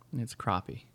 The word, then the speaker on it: crappie: Crappie? Crappie. – But it's spelled with an A. – It's crappie.